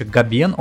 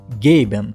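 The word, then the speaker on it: Gaben: Кому, кстати, интересно, по гороскопу Гейб Ньюилл Скорпион, а прозвище Габен он получил из-за своего почтового адреса, который состоит из имени и первой буквы фамилии. По-английски позывной произносится как Гейбен, но в России используют более понятное слуху Габен.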